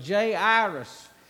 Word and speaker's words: Jairus: But Jairus is how you say this man's name.